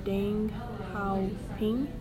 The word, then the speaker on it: Deng Xiaoping: After Mao passed, the new chairman, Deng Xiaoping, is that how you say it? – Yeah.